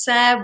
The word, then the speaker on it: Sarah: It's Sarah White,